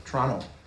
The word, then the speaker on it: Toronto: Toronto. Canadians don't pronounce the T.